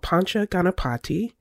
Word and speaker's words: Pancha Ganapati: I believe it's called Pancha Ganapati.